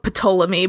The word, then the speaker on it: Ptolemy: It's it's spelled Ptolemy, but yeah, it's a PT name. Experience has has taught me that the P is not pronounced generally.